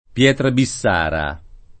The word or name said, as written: pietra bissara